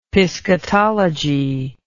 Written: piscatology

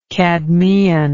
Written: cadmean

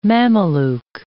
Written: Mameluke

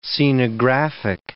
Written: scenographic

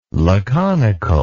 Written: laconical

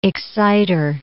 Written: exciter

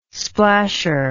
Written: splasher

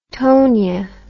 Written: tonia

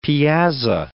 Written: piazza

